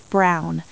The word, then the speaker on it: Brown: My name is Miss Brown. That's B-R-O-W-N.